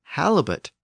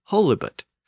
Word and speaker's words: halibut: But halibut is pronounced with a short A sound, halibut. Theoretically, it should be pronounced holibut, but it's a three-syllable word, and over time, that first syllable tended to be pronounced short, so it became halibut instead of holibut.